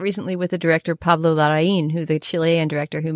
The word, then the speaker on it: Larraín: And if you did a little bit of research into what the accent means, you could figure out that it's Larraín, right, with that accent on the end.